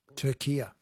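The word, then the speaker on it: Türkiye: But in yesterday's Socceroos update, you kept saying Turkey and not – Turkia.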